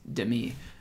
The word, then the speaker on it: Demi: Oh, and you pronounce it Demi. It's not Demi. It's Demi. – So you guys say a little bit quicker.